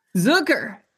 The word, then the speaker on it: Zucker: Zucker. Rhymes with snooker.